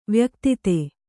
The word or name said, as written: ವ್ಯಕ್ತಿತೆ